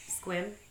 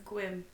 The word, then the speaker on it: Sequim: Squim. – Yeah. But it's spelled Sequim. – Sequim? – Sequim. – Squim. Oh, yeah. That is Sequim.